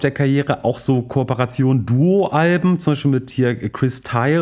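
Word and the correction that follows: Chris Thile: Ich habe in euch gelernt, es wird ausgesprochen Chris Thiele. – Thiele. – Ich dachte auch, es wäre Chris Thiel, wurde in diesem Podcast auch schon mal erwähnt, falsch ausgesprochen, sorry dafür.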